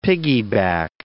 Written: pickaback

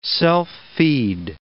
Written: self-feed

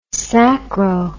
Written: sacro

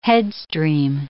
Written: headstream

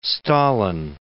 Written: Stalin

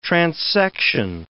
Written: transection

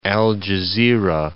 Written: Al Jazirah

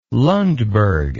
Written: lundberg

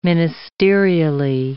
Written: ministerially

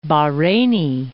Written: Bahreini

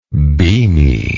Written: beaminess

